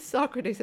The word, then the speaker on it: Socrates: Sorry, his name is Socrates. It's pronounced Socrates.